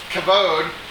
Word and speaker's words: kavod: And The interesting thing about it is primarily in the Old Testament, Strong's word number 3519, we see this word kavod, as I hear it pronounced. It kind of looks like kavod.